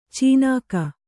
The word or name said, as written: ಚೀನಾಕ